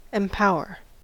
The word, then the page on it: empower: American English